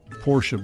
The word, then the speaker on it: Porsche: Porsche, by the way, is the correct pronunciation. – Porsche makes me want to throw up. – It is the correct pronunciation. – Why does the E sound like an A? – It's annoying.